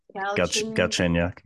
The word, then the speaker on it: Galchenyuk: Galchenyuk, yeah.